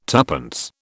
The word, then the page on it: tuppence: British English